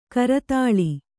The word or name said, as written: ಕರತಾಲ